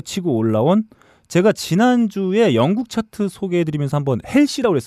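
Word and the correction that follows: Halsey: 그랬었는데 음, 음. 정확히는 뭐 홀시라고 이렇게 발음한다고 예.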